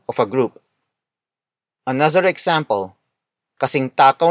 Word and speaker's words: kasing takaw: Kasing takaw ni Robert si Paolo.